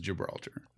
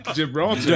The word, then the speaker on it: Gibraltar: But it is Gibraltar. – Just so we're clear. Gibraltar.